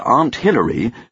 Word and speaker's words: Aunt Hillary: Douglas Hofstadter's Gödel, Escher, Bach includes a sequence where Aunt Hillary, who is an anthill, use the American pronunciation of ant, has a meeting with Dr. Anteater.